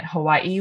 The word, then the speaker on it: Hawaii: I'm also determined to start calling it Hawaii, which I realize is probably really annoying to anyone who's around me, but like that is the correct pronunciation.